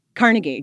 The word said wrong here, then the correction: Kornacki: No, not Carnegie. – Steve Kornacki. – Kornacki.